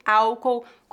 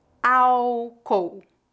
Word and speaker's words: álcool: Lembre-se de que, no Brasil, a letra L em final de sílabas é pronunciada como U. Álcool. Álcool. Nós não pronunciamos o L foneticamente. Não há letra L nesta palavra. Álcool.